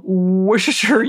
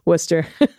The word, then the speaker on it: Worcestershire: So sadly, no pets, but here's a picture of one of my neighbors in beautiful Worcestershire, UK. I know that's not how you say it. – Worcester. – I want to say Worcestershire. – It's cool. – And what is it? What is it? It's worst. It's not Worcestershire, is it? – It's just Worcester.